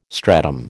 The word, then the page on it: stratum: American English